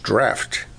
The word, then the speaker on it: draught: That word draught, I'm told, is pronounced draft, not draught.